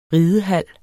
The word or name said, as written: ridehal